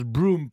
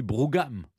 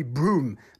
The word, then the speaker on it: Brougham: Mais avant toute chose, son nom, Henry Broom, mais on prononce Broom, pourquoi ? C'est pas, moi j'aurais dit Brougham. – Oui, ça s'écrit Brougham. – Oui. – Alors effectivement en français, cela s'écrit Brougham, et d'ailleurs les Français parleraient plus volontiers d'Henri Brougham. Mais vous l'avez parfaitement bien prononcé, Stéphane, car en anglais, cela se prononce Henry Broom. Broom, d'ailleurs, en insistant sur les « ou ».